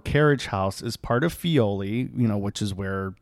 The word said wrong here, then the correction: Filoli: Or is it Filoli or Fioli? – I never know. I always say it wrong. – It's Filoli house, I believe. – Filoli.